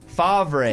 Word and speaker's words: Favre: Yeah, F-A-V-R-E, Favre. – As in Favre. – What'd I say? – That is correct. – You said F-A-R-V-E. – You spelled it the way it sounds, but it's spelled Favre.